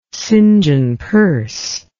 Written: St John Perse

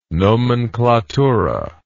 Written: nomenklatura

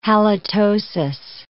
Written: halitosis

